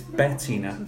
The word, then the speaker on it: Bettina: It's Bettina. – B-E-T-T-I-N-A.